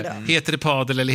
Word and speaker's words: padel: Jag måste bara fråga, vad är det padel? – Ja, nu måste jag också fråga. Faktiskt. – Är det padel? – Ja. – Men enligt Saul så ska det uttalas med långt A.